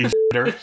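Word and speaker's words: Xitter: And then someone pointed out that in, like, pinyin, like, romanization of Chinese, the X is pronounced S-H. So that would be shitter.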